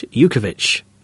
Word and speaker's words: Jutkovic: And having just actually said Lukas Jutkovic, it is, of course, Jukovic. Yes, T is silent.